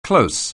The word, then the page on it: close on: anglais